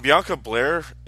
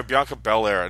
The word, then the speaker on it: Bianca Belair: And Bianca Belair has been what? I'm sorry, Bianca Belair. I knew I was saying it wrong.